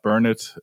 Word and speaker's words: Burnett: og T-Bone Burnett øh, eller Burnett øh, er det nok nærmere det udtales øh, han har godt nok lavet nogle gode ting, øh, må man sige.